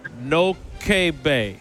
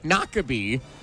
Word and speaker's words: Noquebay: Is it Shawano Lake, Lake Wissota, or Lake Noke Bay? – Nakabee.